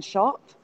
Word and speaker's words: Schaupp: It's an album by Katie Noonan and Karen Schaup, which is spelled S-C-H-A-U-P-P, and it's called The Latin Skies.